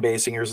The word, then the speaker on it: Basinger: And I looked up her last name to make sure I said it right, and it's Kim Basinger.